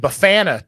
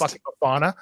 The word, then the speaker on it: bifana: It's pronounced Bifana.